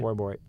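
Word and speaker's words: Forbort: Calgary gets defenseman Derek Faubourt, I think I'm saying that right? – Faubourt. – Okay, I'm close.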